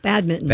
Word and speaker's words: badminton: No, we were talking about, I'm going to pronounce this incorrectly, badminton. You don't pronounce that N? – You do not. – No, badminton.